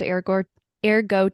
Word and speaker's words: Eire: Right. It's spelled E-I-R-E. Eire is the ancient name of Ireland.